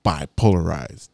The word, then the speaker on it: Bipolaroidz: No, you stress on the bi a lot. – Bi. Bipolar. Bipolar. And that, ladies and gentlemen, was Bipolarized.